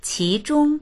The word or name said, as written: qi2zhong1